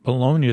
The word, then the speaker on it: Bologna: Bologna is how you say the place in Italy.